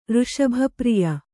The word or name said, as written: ಋಷಭಪ್ರಿಯ